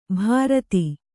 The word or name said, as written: ಭರತಿ